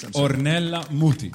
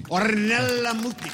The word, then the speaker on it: Ornella Muti: Could you say the name properly, please? – Ornella Muti. – Ornella Muti. Of course.